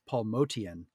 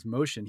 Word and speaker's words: Motian: And his name, you know, pronounced correctly, is Paul Motian. – Right. – And when he was first on the scene, he was going by that. And I think when people started mispronouncing it as Motian, he was like, hey, man, that's pretty hip.